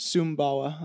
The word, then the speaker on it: Sumbawa: Sumbawa, like S-U-M, Sumbawa, B-A-W-A, I think.